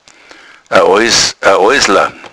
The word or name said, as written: oissla